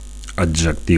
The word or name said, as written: adjectiu